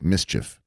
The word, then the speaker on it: MSCHF: MSCHF, which is pronounced mischief.